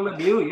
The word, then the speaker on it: Lemieux: His last name rhymes with Schmilu. – Yeah. – Mario Lemieux, yes, came in 282 points in the Quebec League, and unheard of 130 goals, and boy, you thought he would just dominate.